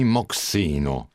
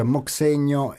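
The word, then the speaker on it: moxeño: Perché scusa, a proposito di strumenti, io qui vedo Carlo Maver, bandoneon, flauto traverso, flauto basso, fin qui ci sono, e poi moxeno, che non so manco se si pronunci così. – Si pronuncia moxegno ed è proprio lo strumento che introduce questo brano.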